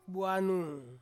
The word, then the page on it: anung: Ansus